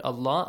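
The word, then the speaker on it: Allah (الله): And also the word Allah, the letter and the word Allah, the letter lam, is pronounced with a light sound or heavy sound? – Heavy. – Heavy, because there fatha. – Is a fatha before la'nata. Okay, if you join it to the word Allah, Allah is pronounced with an a sound.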